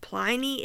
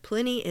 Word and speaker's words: Pliny: I have learned that Pliny is also an acceptable alternate pronunciation of his name, although I have also heard that Pliny is the only correct pronunciation of his name. But either way, it is too late for me. They both sound wrong now.